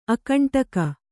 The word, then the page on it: ಅಕಂಟಕ: Kannada